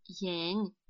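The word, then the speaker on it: янь (颜): Оно будет произноситься как «янь», «янь». Повторяйте за мной третьим тоном.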